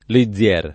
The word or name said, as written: Lizier